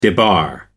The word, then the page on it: debar: American English